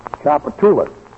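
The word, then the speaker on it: Tchoupitoulas: Chappaculas. T-C-H-O-U-P-O-T. – T-C-H-O-U-P. Oh, no. H-O-P-A-double-T. – No. A-S-H-O-T.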